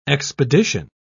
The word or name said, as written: expedition